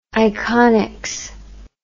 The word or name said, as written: iconics